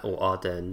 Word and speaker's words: Ardern: Well, I think also, and I just want to say it's Ardern, not Ahern. I got her name wrong.